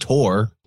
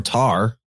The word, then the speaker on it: Thor: We're about to drop the hammer, but Thor, or as you correctly mentioned, Tor or Tar, are other ways of pronouncing this name.